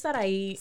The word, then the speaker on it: Sarahy: It's Sarahy.